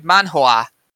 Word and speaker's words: manhwa: However, this time around, we're doing a manhwa, spelled M-A-N-H-U-A, which is a Chinese manga. And not to be confused, as I said, with manhwa, which has, you have to pronounce that H space W-A as opposed to H-W-A.